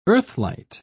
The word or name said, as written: earthlight